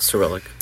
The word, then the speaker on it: Cyrillic: Yeah, Cyrillic.